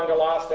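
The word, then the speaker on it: Angelos (ἄγγελος): And as Kai here, the angels, ho, angelos, definite article, A-N-G-E-L-O-S. Angelos, and again, that's actually two gammas, but in the rules of Greek transliteration and pronunciation, whenever you have two hard consonants like gamma or kappa, and the and as preceded, you transliterate the first one as an N.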